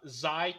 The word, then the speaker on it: Xi: So, very first, we can confirm the pronunciation of the, I'm going to fuck this up, Xi class shuttle. We are going with the Greek pronunciation regardless of what they say on the live stream.